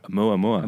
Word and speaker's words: Oumuamua: Oumuamua. – Oumuamua.